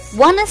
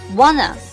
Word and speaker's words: wanna: 好，下面一句 Wanna see us? Wanna 这个单词很多朋友都会读成 wanna，千万不要这么拖长音哈。第一个 a 呢是长音，第二个 a 呢是短音，所以呢是 wanna。